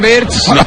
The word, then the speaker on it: Wirtz: Por un lado, Florian Wirtz. – Me encanta cómo lo pronuncias, pero creo que lo pronuncias mal. Porque dices Wirtz, que es Wirtz.